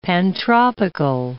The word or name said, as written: pantropical